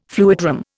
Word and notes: fluidram: Indian

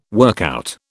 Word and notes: workout: British English

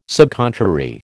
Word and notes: subcontrary: American English

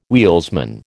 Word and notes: wheelsman: American English

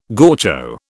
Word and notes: gaucho: British English

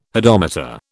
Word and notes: hodometer: British English

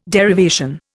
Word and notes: derivation: Indian

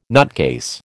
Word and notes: nutcase: American English

